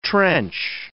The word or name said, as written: trench